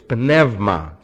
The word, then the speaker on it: pneuma: No silent letters in Greek. All letters are pronounced. This would be pneumatikos. See? Pneumatikos. Pneumatikos. See? Not pneumatikos. Or pneuma for spirit. It's pneuma. See? Pneuma. Hear it?